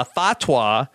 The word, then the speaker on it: Fatwa: So uh, we see Larry go and talk to Jeff about how he's been working on uh, Fatwa. Am I pronouncing that right, Keeve? – Yeah, you got it.